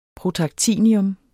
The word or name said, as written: protactinium